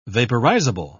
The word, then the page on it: vaporizable: British English